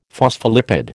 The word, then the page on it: phospholipid: American English